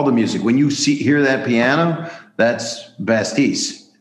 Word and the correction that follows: Batiste: Did I say his name right? Batiste, sorry.